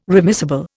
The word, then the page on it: remissible: Indian